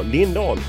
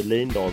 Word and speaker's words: Lindahl: Och efter att hon hade rättat mitt uttal på hennes namn, som ska vara Lindahl och inte Lindahl som jag har trott, så körde vi igång.